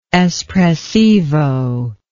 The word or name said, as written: espressivo